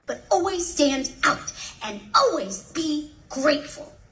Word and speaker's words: stand out: Stand out is another phrasal verb. And in phrasal verbs, we stress the second part. Stand out. Stand out.